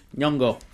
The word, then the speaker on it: Nyong'o: Lupita, I cannot pronounce her last name because I think it's Nyong'o. – Nyong'o. – Okay, that sounds like it.